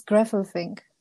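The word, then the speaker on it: Gräfelfing: How do you pronounce that town in Germany? – It's Grafelfing. – Okay. Yeah, it's hard for. – Oh, American. – Sorry. It's the A with the dots. It's like eh.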